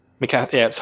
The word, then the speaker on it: Mikasa: But actually, Is it Miska beats them all up? – Mikasa. – I keep saying it wrong.